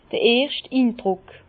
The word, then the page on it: d'r erscht Indruck: Bas-Rhin; Herrlisheim